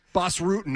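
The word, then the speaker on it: Bas Rutten: Bass Rutten, I'm being told, is how you pronounce his name.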